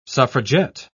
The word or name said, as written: suffragette